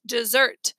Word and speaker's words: Desert: "Southwest Harbor is on an island called Mount Desert," she pronounced it. "Dessert."